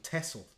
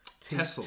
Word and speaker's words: Texel: A place called Texel. – Texel. – T-E-X-E-L.